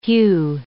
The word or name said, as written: hue